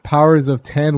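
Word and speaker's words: Powers of X: I well, because as I'm looking into it, I, I guess powers of ten, which this article is scolding me that that's how it's pronounced and House of X uh, take place like at the same time and there's six issues bi-weekly so yes.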